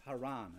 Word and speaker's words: Charan: And it says in Acts 7:4, "Then came he out of the land of the Chaldeans and dwelt in Charan," which is Haran, okay? It's just pronounced, it's spelled differently.